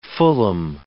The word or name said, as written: fulham